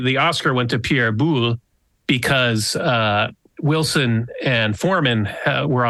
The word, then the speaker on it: Boulle: How is it pronounced? Boulle? – Pierre Boulle. – Boulle.